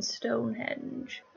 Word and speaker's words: Stonehenge: Henge. No D in Stonehenge.